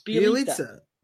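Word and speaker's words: Bjelica: Speaking of guys whose names I cannot pronounce, this next guy who's been picked up in a ton of leagues, Bielitsia. It's pretty close. – You got this one. It rhymes with pizza. It's Bielitsia.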